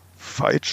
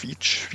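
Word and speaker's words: Veitch: Und ähm, im Auftrag von dieser Gärtnerei, Veitsch spricht man das im Deutschen meistens aus, Veitsch, Veitsch im Englischen, äh, für diese Gärtnerei hat er dann tatsächlich unter anderem diese beiden Anturium-Arten gefunden, die dann später jemand beschrieben hat.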